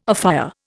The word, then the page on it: afire: Indian